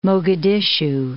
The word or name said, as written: Mogadishu